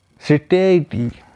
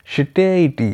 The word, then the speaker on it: satiety: Fourth word सिटेटी, इसका spelling होता है SATIETY, सिटेटी, इसका प्रोनाउंसिएशन ही ऐसा होता है सिटेटी, SATIETY, सिटेटी, इसका अर्थ होता है परितृप्त, मतलब सेटिस्फैक्शन, फुलफिलमेंट या इजीनेस, ये सब सिनोनिम्स में आ गए।